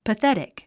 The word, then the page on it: pathetic: pəθétik; パセティク